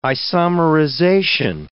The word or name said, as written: isomerization